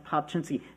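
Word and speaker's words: Papczyński: I know that last name is hard to pronounce. It's pronounced Popchinski. This is how I remember. It took me 5 years to remember our founder's name. So this is what you do. You do Popchinski, like you're skiing.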